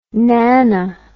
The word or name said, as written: nano